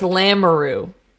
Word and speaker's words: Lamoureux: So I just think that they are these unbelievably Mario Lemieux's kids? – No, it's Lamoureux. – Lamoureux. – Or Lamoureux. I can't pronounce it. It's French.